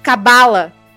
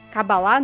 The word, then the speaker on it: Cabala: que é o Kabbalah. Ou Kabbalah, não sei como que pronuncia essa porra. – Cabala. – Cabala. Cabalá não, né? Cabala. – Acho que é Cabala. É, é Cabala.